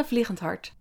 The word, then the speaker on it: Vliegenhart: You told me, say it in your beautiful, in your accent though. – So full name is Sana Vliegenhart. – Oh, beautiful. The soft G there, unapproachable.